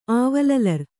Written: ಆವಲಲರ್

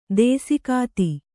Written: ದೇಸಿಗಾತಿ

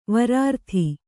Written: ವರಾರ್ಥಿ

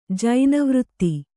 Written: ಜೈನವೃತ್ತಿ

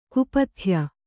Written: ಕುಪಥ್ಯ

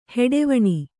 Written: ಹೆಡೆವಣಿ

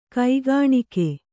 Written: ಕೈಕಾಣಿಕೆ

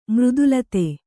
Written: ಮೃದುಲತೆ

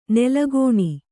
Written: ನೆಲೆಗಾಣು

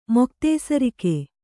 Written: ಮೊಕ್ತೇಸರಿಕೆ

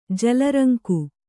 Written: ಜಲರಂಕು

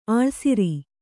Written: ಆಳ್ಸಿರಿ

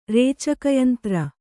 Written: ರೇಚಕಯಂತ್ರ

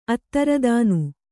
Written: ಅತ್ತರುದಾನಿ

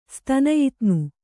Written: ಸ್ತನಯಿತ್ನು

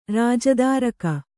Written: ರಾಜದಾರಕ

